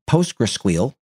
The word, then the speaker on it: PostgreSQL: because despite the fact that they have PostgreSQL, yes, that's how I pronounce it,